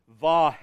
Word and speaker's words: Vav: And those letters are Yod, He, Vav, He. That's how they're pronounced.